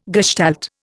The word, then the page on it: gestalt: Indian